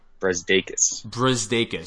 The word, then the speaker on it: Brazdeikis: How do you pronounce his last name? – Brzdakis.